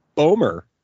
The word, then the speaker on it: Bomer: Anyways, Matt Bomer. Is that the way you pronounce it? – Bomer. – Matt Bomer.